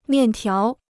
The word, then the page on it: 面条: miàn tiáo